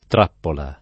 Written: trappola

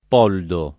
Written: Poldo

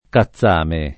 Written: cazzame

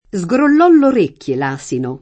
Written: scrollare

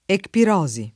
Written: ecpirosi

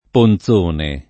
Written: Ponzone

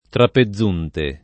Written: Trapezunte

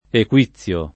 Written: Equizio

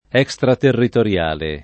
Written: extraterritoriale

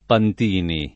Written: Pantini

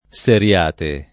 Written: Seriate

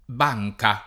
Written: banca

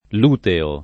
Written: luteo

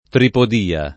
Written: tripodia

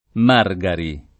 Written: Margari

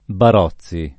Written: Barozzi

